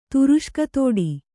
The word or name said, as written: ತುರುಷ್ಕತೋಡಿ